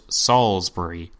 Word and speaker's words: Salisbury: Instead, his name is pronounced Salisbury. I lament at the ridiculousness of these people's names, but I want to present this series to you properly, so there it is, Salisbury.